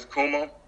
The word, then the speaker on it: Kumueh: But my name is Kumueh. Pronounced like Puma, just don't forget the K.